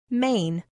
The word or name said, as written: main